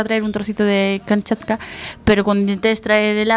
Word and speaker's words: Kamchatka: Kamchatka. Lo acabo de pronunciar fatal, que es Kamchatka.